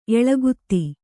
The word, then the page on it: ಏಲಾಗೀತೆ: ēlāgīte